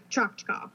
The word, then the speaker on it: Choctaw: The Chickasaw and Choctaw Indians, I hope I said that right. Yeah, Choctaw.